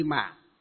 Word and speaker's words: mạng: Nó vẫn là một cái chữ Hán ấy, nhưng ở miền Bắc chúng ta đọc là mệnh, còn ở miền Nam đọc là mạng.